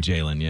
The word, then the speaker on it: Jalon: J-A-L-O-N. – I know, I think it's Jalen. – It is. – They always said Jalen, yeah. – Why do you say Jalon then? – Because it's J-A-L-O-N. – It's spelled that way.